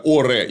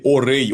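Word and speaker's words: Auray: И для того, чтобы размять некоторым образом договороспособность Карла Блуаского, осаждают местечко Оре или Орей. Он А-У-Ре-И, Игрек на конце пишется. У нас обычно его Оре пишут, но, по-моему, можно сказать и Орей.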